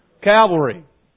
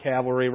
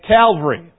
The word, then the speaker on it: Calvary: You commonly hear it referred to as what? Calvary. Not cavalry riding on a horse. Cavalry. Okay? I said it wrong. You know what I mean. There's a difference between cavalry and Calvary.